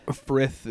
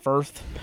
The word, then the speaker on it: Frith: Where it's, like, you got Frith, I think is how you say it. – Frith is the god. – I was just constantly going back and forth between Frith and Firth.